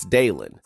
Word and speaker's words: Dylan: My first name is spelled D-Y-L-A-N. And it's not Dylan, it's Dalen.